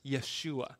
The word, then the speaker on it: Yeshua: The name Jesus is the Greek version of the Old Testament name of Joshua, uh, and the Hebrew pronounce it as Yeshua.